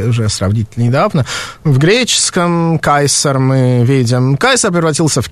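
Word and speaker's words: Caesar: Ну да, и современные итальянцы произносят это имя как Чезаре, да, Рим Римляне, видимо, произносили «кайсар».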